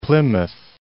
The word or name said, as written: Plymouth